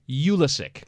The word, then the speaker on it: Pulisic: They emphasize the U, Pulisic, as opposed to Pulisic.